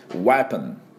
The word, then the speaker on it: weapon: で、同じで、ウェポン。ウェポンという音になります。